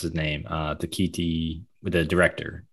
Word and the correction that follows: Taika Waititi: Taika Waititi?